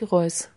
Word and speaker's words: Reuss: Wie spricht man das aus? Reuss, ne? – Sophie Reuss. – Ja, da hätte ich auch überlegt, ob das jetzt Französisch ist oder nicht. Roy oder.